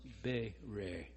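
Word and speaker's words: Bere (ברא): But if you add an A after the first consonant and an A after the second one and give them a long A sound in English, it becomes Bere.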